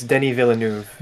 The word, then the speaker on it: Denis Villeneuve: Is it Denis Villeneuve? – I think it's Denis Villeneuve. – There it is.